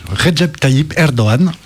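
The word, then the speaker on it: Recep Tayyip Erdoğan: Et alors là, attention, Radio Canu est la seule radio qui prononce correctement le nom de ce premier ministre. Et pas Recep non plus. Donc Recep Tayyip Erdogan, fragilisé par les scandales.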